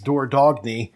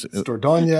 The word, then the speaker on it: Dordogne: Is a very commonly known Dordogne. – Gesundheit. – No one would pronounce this as Dordogne.